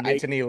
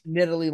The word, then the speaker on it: Nittany: And I also wanted to say quickly, on the video we posted when he after the thing, I forgot to say knitting, knitting. You see, this is why I didn't put it in there. – Nittany Lions. – Nittany Lions.